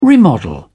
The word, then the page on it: remodel: British English